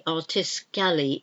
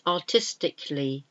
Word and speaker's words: artistically: That last a, artiscally, is not pronounced, so it's artistically.